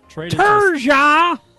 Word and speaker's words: Turgeon: On October 29th, 1996, Craig Conroy and Pierre Turgeon, or how would Ken Wilson say it? – Pierre Turgeon. Turgeon! – Yeah, doesn't pronounce the N.